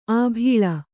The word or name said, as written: ಅಬಳ